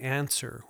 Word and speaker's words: Anser: Now, for all you tech heads out there, the Answer, which is spelled A-N-S-E-R,